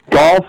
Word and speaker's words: Gough: So, Golf Livery Carriages, 906. – And for the listener that doesn't know, it's spelled G-O-U-G-H, correct? – Yep, correct. Yep. It's pronounced golf, but yep.